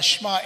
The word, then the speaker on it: Shema: It's S-H-E-M-A, pronounced Shema. You say it fast.